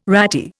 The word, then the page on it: ratty: Indian